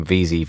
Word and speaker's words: Veazey: The advantages of an unspellable name is like my name, Veazey, V-E-A-Z-E-Y.